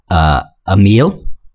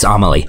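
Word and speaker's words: Amélie: So mine is, and I always mispronounce it, Amelie. It's Amelie.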